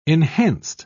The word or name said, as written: enhanced